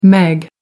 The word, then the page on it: meg: American English